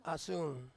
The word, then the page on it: asun: Ansus